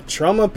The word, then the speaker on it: Troma: Troma pops up. – Maybe you're spelling. – How are you spelling it? – Tromo. You just pronounced it. – But I think it is Troma, like Troma. I think it is Troma. – Is it Troma? Have I just been mispronouncing it? – Yeah, time?